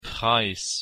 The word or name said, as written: Preis